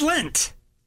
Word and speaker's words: Lent: It's Lent. – Not Lent. It's Lent. Like lentils.